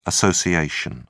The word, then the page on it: assoc: British English